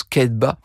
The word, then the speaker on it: Cathbad: Ça s'écrit Cathbad, mais ça se prononce Kedba.